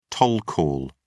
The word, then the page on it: toll call: British English